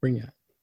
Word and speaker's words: Brignac: Brignac.